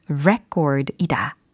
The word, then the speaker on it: record: 이름절 강세다. 그리고 모음의 발음이 record이다. record가 아니다 라는 것까지 설명을 드렸습니다.